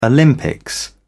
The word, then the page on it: olympics: British English